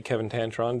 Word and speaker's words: Tancharoen: but I'm sitting with my buddy Kevin Tancharoen. Did I pronounce that right? – You did. – Like, actually, that's it, right? – That's correct.